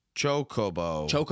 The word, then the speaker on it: Chocobo: Chocobo.